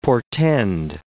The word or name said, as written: portend